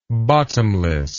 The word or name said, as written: bottomlessness